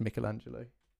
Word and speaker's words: Michelangelo: I know it's pronounced Michelangelo, but no one says that.